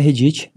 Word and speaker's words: Reddit: É Reddit, é? – No Reddit.